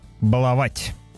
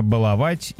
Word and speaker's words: баловать: Филологи изменят ударение в слове «баловать». Все меньше мы будем встречать в разговорной, живой, обиходной речи ударение «баловать» и «баловаться». Наверное, только о тех, кто привык так говорить, потому что знает, что так правильно, или хочет щегольнуть таким образцовым ударением. Очевидно, что будущее за ударением «баловать». «Баловаться», заявил научный сотрудник Института русского языка Пахомов. Сейчас в орфоэпическом словаре русского языка как государственного варианта «баловать» и «баловать» даются как равноправные.